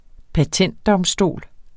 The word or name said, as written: patentdomstol